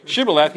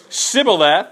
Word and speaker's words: Shibboleth: So what would have happened when Kyle tried to say Shibboleth? He would have passed, because he said it correctly, actually. So you're still alive. Okay, but when they would say Sibboleth, they couldn't pronounce the SH, and in Hebrew it would have been a completely different sound, so I'd have no idea what it is.